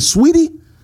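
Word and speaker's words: Saweetie: Is it Sweetie? So what the, why is it spelled S-A-W? – Sweetie.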